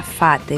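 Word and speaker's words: fat (髮): En cantonés el cabello se pronuncia fat, es un sonido que suena similar al de la expresión de hacerse rico.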